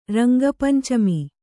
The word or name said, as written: ರಂಗಪಂಚಮಿ